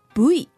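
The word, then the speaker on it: ブイ: Yeah, actually, we don't have the pronunciation of the V. So we call Bui.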